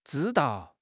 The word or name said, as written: zhi1 dao4